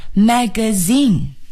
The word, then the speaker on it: magazine: 请朋友们注意啊， magazine 它的重音呢是在第三个音节，也就是 zin 上面，那么前面呢 mag 都不要重读，我们把最重的这个读音呢要放在 zin 上，大家试一下 magazine, magazine.